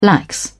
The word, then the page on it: lax: British English